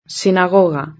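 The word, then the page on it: la sinagoga: Spanish (Spain)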